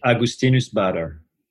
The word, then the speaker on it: Augustinus Bader: So I will pronounce it Augustinus Bader. That's my way, but I'm French again. So, you know, he's German.